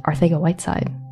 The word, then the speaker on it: Arcega-Whiteside: Bill, a feather in your cap is how you correctly pronounced Arcega-Whiteside.